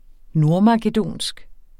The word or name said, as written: nordmakedonsk